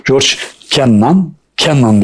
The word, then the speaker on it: George Kennan: Amerikan Dışişleri Bakanlığı Siyaset Planlama Müdürü George Kennan diye okunuyor.